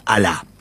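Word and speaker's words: alá (ἀλλά): Él utiliza de las cuatro conjunciones adversativas que hay en el griego, él utiliza la más fuerte, que es alá. Se escribe A-L-L-A, ¿verdad? Como aliá, pero es sí, alá.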